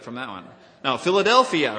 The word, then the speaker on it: Philadelphia: And I looked it up in Strong's, and you pronounce it like this. Phil-ad-el-phia.